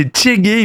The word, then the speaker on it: Tshegue: C'est le nom du projet, c'est Tchégué, il me semble, ça se prononce comme ça. T-S-H-E-G-U-E.